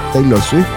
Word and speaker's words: Taylor Swift: Opalite, se llama este tema de Taylor Swift en este edificio de artistas. ¿Lo dije bien? Taylor Swift.